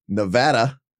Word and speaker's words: Nevada: But David, let us begin with the drive to the Nevada caucuses, I said that right, Nevada, which take place at noon Pacific time on Saturday.